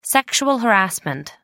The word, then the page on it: sexual harassment: American English